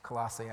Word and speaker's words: Colossae: Colossae is the correct way to pronounce it, I just learned.